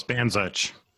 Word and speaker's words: Bandzuch: Am I pronouncing that correctly, Nancy? – Close, Bandzuch.